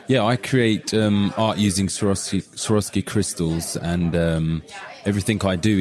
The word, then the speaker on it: Swarovski: I always thought it was Swarovski art. But welcome to the show, and maybe you can help me pronounce this correctly. – Hi, yes, It's actually Swarovski.